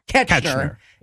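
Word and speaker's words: Koechner: David Kochner, is that how that name's pronounced? – Ketchner. – Ketchner.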